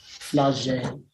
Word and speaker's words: Flagey: 5th, we're in Brussels at Flaggy. Am I saying it right? Flagey. – I've got no idea. – Yeah, it's flage. It's like, it's very Frenchy.